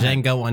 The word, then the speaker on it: Django: Django Unchained, baby. The D is silent.